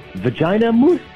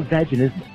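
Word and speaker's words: vaginismus: Oh, so what you're saying is vagina moose was not the right way to pronounce that. – That's not vaginism. The condition.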